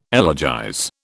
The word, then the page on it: elegize: British English